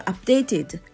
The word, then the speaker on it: updated: で、えー、 keep me updated という言い方のにする時にはこの updated の、えー、発音なんですけれどもこちらはあアップの P の音ですよね。 updated updated updated って